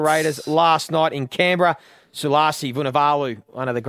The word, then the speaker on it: Sulasi Vunivalu: More impressive is your pronunciation. – Thank you. – That was good. – We'll add it again. – Sulasi Vunivalu. – Oh, I like it. – You've just nailed that.